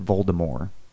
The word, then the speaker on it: Voldemort: She, she intended his name the last part of the name, the M O R T, to be like the French word for death. So the T is silent. She pronounces it Voldemort.